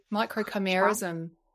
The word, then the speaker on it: microchimerism: Is it pronounced microchimerism? – Yeah.